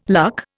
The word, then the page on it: luck: Indian